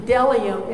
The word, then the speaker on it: Bdellium: In the little spice jars, the little beads, like kind of a pearl because they're round, and has an appearance of bdellium the B in Bdellium is silent.